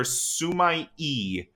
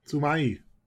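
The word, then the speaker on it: sumi-e: A Japanese ink illustration known as ink wash painting or sumai-e, S-U-M-I-E. Is that how do you pronounce that? – Sumai. – Okay, is that how you say that? Okay. Sumai-e.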